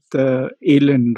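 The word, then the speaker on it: Allen: So right now you're doing a PhD in NLP, and you're also working with ALEN, right? That's the right pronunciation.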